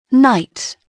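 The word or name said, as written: knight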